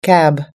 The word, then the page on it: cab: American English